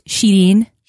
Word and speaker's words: Shireen: That's the Americanized version, but it's really pronounced Shireen.